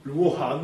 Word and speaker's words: Luoran: Donc, moi, je prononce Luoran. – Luoran, non, c'est bon.